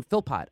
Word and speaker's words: Philpott: Professor Dan Philpott.